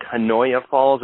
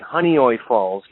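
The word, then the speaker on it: Honeoye Falls: It looks like Hanoya Falls. It's actually Honeyoy Falls, New York.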